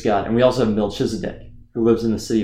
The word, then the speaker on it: Melchizedek: Melchizedek. I've never heard the ch pronounced. – I think it's Melchizedek.